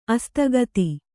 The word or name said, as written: ಅಸ್ತಗತಿ